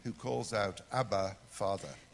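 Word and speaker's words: Abba (אבא): In Hebrew they would pronounce it Abba.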